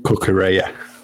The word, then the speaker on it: Cucurella: Brighton's average positions, they had 6 players in the Tottenham half, and that's including, you know, Lamptey and you know, the other Cucurella, wasn't it, on the other side. Was it, could you have, absolutely murdering the pronunciation there again? – Kukureya, that's the one.